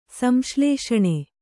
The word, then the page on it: ಸಂಶ್ಲೇಷಣೆ: samsḷēṣaṇe